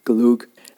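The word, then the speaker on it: gløgg: Glug, as they pronounce it, funny enough.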